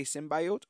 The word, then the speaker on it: symbiote: The alien symbiote. Mm-hmm. And turns out both pronunciations are correct. Oh. You can say symbiote or symbiote. So I'm going to go back to symbiote because I just like it that way.